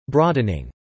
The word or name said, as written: broadening